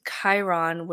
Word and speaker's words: Chiron: And so for this story, we're going to turn to Chiron, and it's spelled C-H-I-R-O-N. It's pronounced Chiron, who's a centaur.